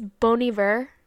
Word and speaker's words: Bon Iver: Like, okay, I was told it's Bon Iver.